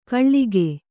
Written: ಖಳ್ಗಿ

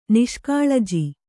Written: ನಿಷ್ಕಾಳಜಿ

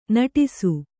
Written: ನಟಿಸು

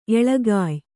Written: ಎಳಗಾಯ್

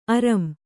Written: ಅರಂ

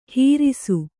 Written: ಹಿರಿಸು

